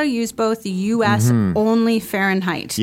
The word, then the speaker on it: Fahrenheit: And I just want to talk a little bit about the pronunciation of Fahrenheit again. – Mm-hmm, yeah. – So it's like Fahrenheit. – Fahrenheit. – Right. – Yeah. – So lots of extra letters there, but Fahrenheit.